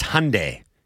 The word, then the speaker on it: Hyundai: That's Hyundai.